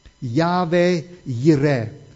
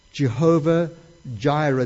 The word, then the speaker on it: Yahweh Yireh: Then Abram called that place Yahweh Yireh, Jehovah Jireh, the American pronunciation.